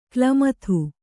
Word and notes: ಕ್ಲಮಥು: klamathu

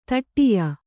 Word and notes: ಧಡಿಯ: Kannada

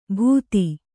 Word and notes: ಬೂದಿ: būdi; Kannada

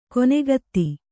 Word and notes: ಕೊನೆಗತ್ತಿ: konegatti

